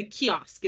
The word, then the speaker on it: kiosk: It's a kiosk, isn't it? – Koisk, a small open-fronted hut or How is cubicle. This spelled? – K-I-O-S-K. – That's kiosk. – Kiosk. – What the hell am I saying?